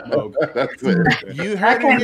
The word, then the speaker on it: Moog: What he explains in there is it's a Dutch name, and in Dutch it's pronounced Moog.